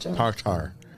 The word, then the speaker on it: TARTA: And that's TARTA. – Not tartar, like tartar sauce.